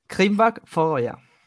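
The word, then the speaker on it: Kringvarp Føroya: ¿Has mirado cómo se, cómo se pronunciaba? Sí, así es. – Repítelo, por eh, favor. – Krimbach Fogoya. Oh.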